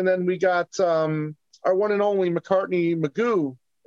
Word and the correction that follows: McGough: Is that how you pronounce the name? – I think it's McGough. – McGough, right.